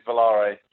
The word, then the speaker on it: Volare: It's Italian for fly, so it's open to interpretation as to what accent you're using as to how you pronounce it. But my English take on the Italian of it is Volare.